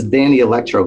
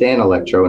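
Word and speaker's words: Danelectro: I said, what was this Danny Electro guitar you let me use? He goes, it's pronounced Dan Electro.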